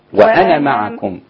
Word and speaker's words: وأنا: ده بيقول لك إنه لو في الوصل هذه الألف لا تنطق. – لا تنطق. – وإذا وقفتي يبقى لازم وأنا يبقى أثبت الألف وقفا وصلا لا. – وأنا معكم كأن النون مفتوحة بعدها ميم على طول.